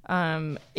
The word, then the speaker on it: Imgur: Imgur? – Well, it's spelled I-M-G-U-R. And I always thought it was Imgur. – Imgur. – But now that I say it out loud, that just sounds dumb.